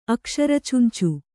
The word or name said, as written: ಅಕ್ಷರಚುಂಚು